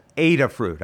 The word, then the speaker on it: Adafruit: The place is called Adafruit. I'll spell it ADA. That's ADA, ADA, Alpha Delta Alpha. Adafruit, like raspberries are a fruit. Adafruit.